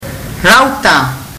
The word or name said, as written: rauta